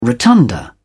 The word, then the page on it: rotunda: British English